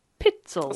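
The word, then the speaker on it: Pitezel: So it's P-I-T-E-Z-E-L. Pitzel. – It's like Pitzel.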